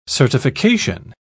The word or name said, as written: certification